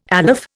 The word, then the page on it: aleph: Indian